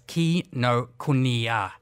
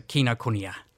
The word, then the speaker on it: Kinokuniya: Well, I've probably my Australian accent and slight Kiwi accent is butchering it, but it's Kinokuniya. So Kinokuniya.